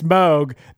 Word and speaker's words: Moog: Hey, oh, real quick, Chris, by the way, it is Moog. It's not Moog. It's Moog. I went on their website and they said it's Moog that rhymes with Vogue.